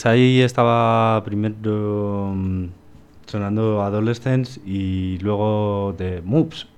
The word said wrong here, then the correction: Muffs: No sé pronunciarlo, pero bueno, da igual. – Muffs. – El caso, Muffs. – Muffs. – Muy bien, dilo. – Muffs. – Muy bien, dilo, bien.